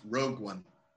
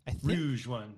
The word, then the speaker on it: Rogue One: It's pronounced Rogue One. – Rogue One. There we go. – Rouge One.